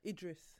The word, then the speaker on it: Idris: Say Idris or Idris? – Idris. – I know someone that says Idris, and I want to punch him every time he says it. – Even he pronounces his name Idris.